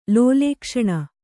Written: ಲೋಲೇಕ್ಷಣ

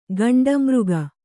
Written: ಘೇಂಡಾಮೃಗ

